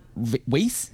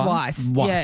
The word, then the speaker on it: Weisz: man, which is a woman, Rachel Weisz. Have I said that right? – Well, I think it's Weisz.